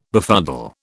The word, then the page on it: befuddle: British English